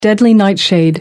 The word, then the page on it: deadly nightshade: American English